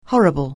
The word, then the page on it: horrible: anglais